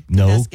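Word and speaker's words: Kenduskeag: But before we get there, Kanduskeg, did I say that correctly? – You did. Excuse me. – Kanduskeg.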